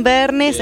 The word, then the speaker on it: Vergnes: Bueno, a través de Catherine Bernes, es mi nombre, se escribe con C, T, H, B corta.